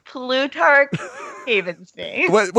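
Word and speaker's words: Plutarch Heavensbee: Plutarch Havensby.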